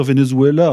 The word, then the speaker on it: Venezuela: Vous savez, Monsieur Debrac, on peut dire Venezuela, hein, c'est une prononciation un peu à la, sud-américaine, je pense, c'est pas...